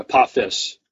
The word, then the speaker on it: Apophis: I pronounced it Apothis, And he says, many people make this mistake, but it's actually Apophis.